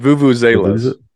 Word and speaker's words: vuvuzela: It's vuvuzelas.